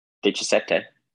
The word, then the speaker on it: Diecisiete: Diecisiete.